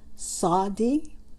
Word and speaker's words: Tsadde: It's the Hebrew letter is Sadi. It's spelled with a T, which is silent, T-S-A-D-D-E.